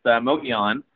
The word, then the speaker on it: Mogollon: Well, let's transition over to the other project the company has over in New Mexico, and that's the high-grade silver-gold Mogollon property. I hope I'm pronouncing that right. – Yeah, that's a tough one to pronounce. It's Mogollon